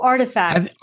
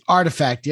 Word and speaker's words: RTFKT: Oh, Artifact. – Artifact.